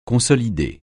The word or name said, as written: consolider